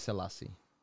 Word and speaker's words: Selassie: My name is like Selassie, S E L, uh, A S S S S E Y E mm-hmm. – and they are always mispronouncing or mis mis typing it to S E L L, like two L's instead of two S's.